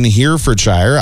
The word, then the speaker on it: Herefordshire: The popular Scoop ice cream shack in Herefordshire, I actually said that properly. Yeah, good job, I think that's it.